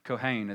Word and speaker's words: Cohen: My name Cohen is actually a Jewish name. It's usually a Jewish last name, actually. It means priest, actually. Kohen is how it's said.